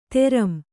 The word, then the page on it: ತೆರಂ: teram